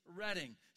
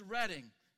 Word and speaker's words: Reading: It is Redding. So if you get nothing else out of coming today, you have learned how to say it's Redding.